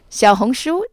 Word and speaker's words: Xiaohongshu: Xiaohongshu, that's spelled XIAOHONGSHU literally means Little Red Book in Chinese, although the company's English name is simply Red.